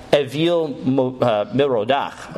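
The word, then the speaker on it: Evil-Merodach: Okay, that's how you would actually say his name in Hebrew. But it's spelled evil, Merodach. So everyone wants to pronounce it evil, which is great because it makes him look like he's an evil guy because he's Babylonian.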